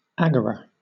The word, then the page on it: agora: British English